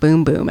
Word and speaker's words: Bum Bum: I think it's pronounced Boom Boom, actually.